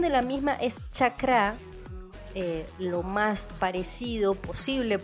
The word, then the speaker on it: chakra: pero lo que más se le parece a la pronunciación del mismo es chakra.